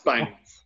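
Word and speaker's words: Bangour: Now, I can almost hear a Welsh correspondent, Robin Pearce, shouting at the computer now, saying, but Bangor, surely it's in Wales. What is a hospital called Bangor doing in Scotland? – It's got a U in Bangor. It's B-A-N-G-O-U-R. But it's pronounced Bangor.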